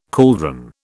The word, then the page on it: cauldron: British English